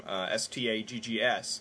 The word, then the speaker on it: Staggs: You know, I've got to tell you, I had a hard time growing up with my last name, STAGGS, which not only does it rhyme with a very unkind word for homosexuals, it's also just an unusual name in itself.